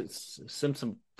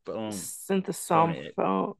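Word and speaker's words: Sinthasomphone: Sorry, I know I butchered that. – Synthesomphone. – Synthesomphone. Yeah.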